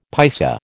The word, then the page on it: paisa: American English